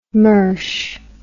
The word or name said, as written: mersh